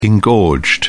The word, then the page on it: engorged: British English